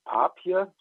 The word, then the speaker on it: Papier: ist der Hans-Jürgen Papier, so spricht man den aus, Herr Wilmsmann, ja, genau.